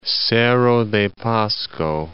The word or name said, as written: Cerro de Pasco